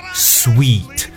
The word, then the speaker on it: suite: S U I T E，首先这个单词的这个说法呢，要记住它的读音叫 sweet，S U I T E，OK，不是 S U I T，S U I T 叫 suit，right，就像西裝或套裝，but S U I T E 这个单词的读音叫 sweet，就跟甜的发音是一样的，sweet。